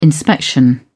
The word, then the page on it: inspection: British English